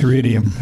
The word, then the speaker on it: Iridium: It says my Camry has to have, Iridium? Is that how it's pronounced? – Iridium.